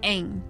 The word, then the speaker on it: Aang: Let's start with Aang.